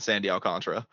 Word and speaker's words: Alcantara: It's spelled as Alcantara. Yeah. A lot of people say Alcantara, but it's Sandy Alcantara.